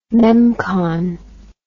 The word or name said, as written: memcon